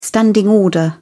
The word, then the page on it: standing order: British English